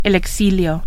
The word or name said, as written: exilio